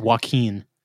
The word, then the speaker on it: Joaquin: How do you say his name? Joaquin? – I don't know why you have such a problem with this. It's Joaquin. – Joaquin, because it doesn't look like the word Joaquin.